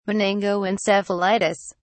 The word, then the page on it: meningoencephalitis: British English